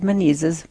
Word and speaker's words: Menezes: Father Wade Menezes, rhymes with diseases, is going to be doing a retreat here at the end of the yes.